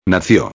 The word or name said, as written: nacio